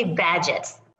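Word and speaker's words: Bagehot: At the Far Eastern Economic Review, and was also a Knight Bagge Hot Fellow. Is that right? – They say Badgett.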